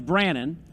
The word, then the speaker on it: Brannon: All right, and mine's Brannon. – Brannon, no Brandon. – No D in there.